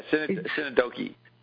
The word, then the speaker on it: synecdoche: They say synecdoche, or I don't know how you say it. – Yeah, synecdoche.